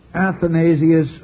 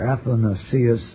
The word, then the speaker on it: Athanasius: It was the supreme achievement of Saint Athanasius of Alexandria, or Athanasius of Alexandria. You'll hear him pronounced both ways, as well as other ways.